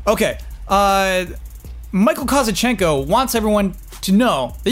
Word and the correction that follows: Kozachenko: And also, apparently, we've been pronouncing his last name wrong. – Oh, yes. – So is it Kozachenko? – It's Kozachenko, correct.